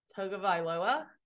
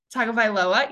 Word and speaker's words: Tagovailoa: I finally have learned how to pronounce Tua's last name. – Tagovailoa. – Tagovailoa, yes.